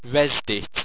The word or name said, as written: resdyt